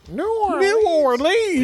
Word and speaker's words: New Orleans: I don't care if they're in the Nolans. What do they call it? Nolans what? – Nolans. – Yeah, that's right. How do you say it? – New Orleans. – Yeah, exactly.